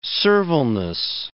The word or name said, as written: servileness